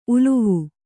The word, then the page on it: ಉಲಿವು: ulivu; kannada